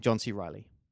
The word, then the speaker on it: Reilly: and John C. Reilly. – Right, I really expected you to stuff up a pronunciation there. We were all dreading this, but actually, surprisingly, you got John C. Reilly perfect.